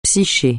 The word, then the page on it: psyché: francais